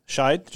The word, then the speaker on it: Scheid: So Tony Schneid. – Scheid. – Just Scheid. No S. No, the C is silent.